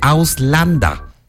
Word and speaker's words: Ausländer: Ausländer. – Casi. Me da permiso de echarle extraño la manita con la pronunciación. – Ok. – A ver, se dice Auslanda.